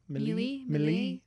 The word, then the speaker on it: Mele: It's not Melee, but I don't know how to pronounce her last name, and I apologize, Dana. – Melee? – M-E-L-E. – There you go.